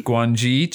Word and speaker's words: Guangxi: Guangxi,